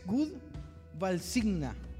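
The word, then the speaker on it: Gud välsigna: Entonces, Dios bendiga es good valsigna. Valsigna, más, sentado al principio. Good Valsigna.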